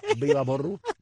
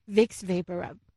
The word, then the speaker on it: Vicks VapoRub: ¿Y cómo es que se pronuncia? – Vicks Vaporub.